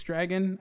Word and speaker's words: Drago: Nicholas said Draco was the best dragon in movies. Drago. Yep. Drago. Yes. Sorry, I'm mispronouncing it.